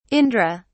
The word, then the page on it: indra: British English